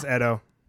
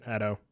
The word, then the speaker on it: Edo: Yeah, no, it is Edo. Not Edo. – So really? Fucking smart. – Yeah, that's how you pronounce it, Edo.